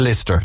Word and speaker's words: Lyster: L-Y-S-T-E-R, but he pronounces it Lister, like sister. – Yeah. – Or Blister.